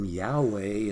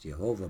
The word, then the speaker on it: Yahweh: I was mentioning that his name Yahweh in the original, which is also sometimes pronounced Jehovah by others, but that's not as accurate, means the ultimate reality, the I am that I am, separate and above and beyond creation.